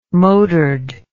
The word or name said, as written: motored